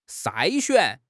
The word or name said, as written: 筛选